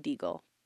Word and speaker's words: Deigl: So this case is about 27-year-old Andre Deagle, which is spelled D-E-I-G-L. Doesn't seem like it'd be Deagle, but it's Deagle.